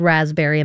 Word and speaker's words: raspberry: I got second place in the spelling bee because of the word raspberry and the silent P.